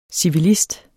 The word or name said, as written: civilist